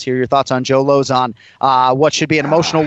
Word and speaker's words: Lauzon: We call him Lozon in the UFC. It's one of the easier names to pronounce, incidentally. Just say opposite of high, it's Lozon.